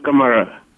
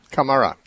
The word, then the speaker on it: Kamara: This is, now I don't know if I'm going to put the emphasis on the right syllable, but is it Kamara or Camara? – Yes, Kamara. – Kamara, yes, welcome.